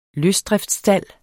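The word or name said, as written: LØSDRIFTSSTALD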